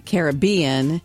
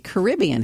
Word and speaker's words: Caribbean: Some people say Caribbean and some people say Caribbean. Have you noticed that? You see it on commercials all the time. So in the U.S., most native speakers and many Caribbean locals say Caribbean, aligning with the indigenous root of the Carib people. But in the U.K., they say Caribbean, which is more common for them.